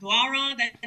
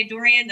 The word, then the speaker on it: Doiron: My father is probably very disappointed in me, but Doiron, that's the way to pronounce it. If you want to say Dorian, that'll save you some time.